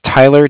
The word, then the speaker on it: Tyler: My name is Tyler, T-Y-L-E-R. And I'm calling with Cardiff. It's spelled C-A-R. D-I-F-F, as in Frank Frank.